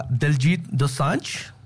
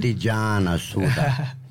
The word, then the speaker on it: Diljit Dosanjh: How do you say it? – Diljit Dosanjh. – I said it right. What are you talking about? – You said Dijanasura. That's totally different.